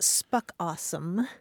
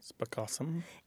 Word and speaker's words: spookasem: There's a South African term that's spelled S-P-O-O-K-A-S-E-M. And it's pronounced spuck awesome. – Spuck awesome.